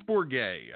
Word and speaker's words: Bourget: Is it Bourget or is it Bourget? – I think it's Bourget. That's how I believe he told me.